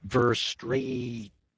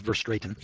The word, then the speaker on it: Verstraeten: That was Dr. Thomas Verstraetien, I guess that's the way you pronounce his name, V-E-R-S-T-R-A-E-T-E-N, Verstraetien, I guess it's Verstraetien,